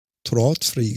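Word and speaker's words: Tradfri: zwar, da hat, da hat ja IKEA seine Smart-Beleuchtung rausgebracht, was Tröttfree, Tratfree, Tröttfree? glaube ich. – Tröttfree. Tröttfree, genau.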